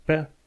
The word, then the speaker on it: pear: Uite, homofone, homofone, pardon, se scrie uh, o pereche, zice pair, dar se scrie p a r Dar pară în limba engleză se scrie p e a r Se citește tot pair. Deci pair, pair.